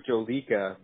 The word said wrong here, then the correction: Jolokia: but I think it's Jolokia.